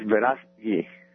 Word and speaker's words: Verástegui: Let me see if I pronounce it right, Eduardo. Verástegui? – It's Verástegui. – Verástegui. – Verástegui. Very good.